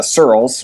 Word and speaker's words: Searls: So my last name is uh, Searls, which is spelled like the word pearls, but with an S instead of a P.